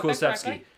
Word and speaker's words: Kulusevski: You're bringing in Kulusevski, if I'm pronouncing that right, from Juventus. – Yep, you got Kulusevski. That correctly. That's the one.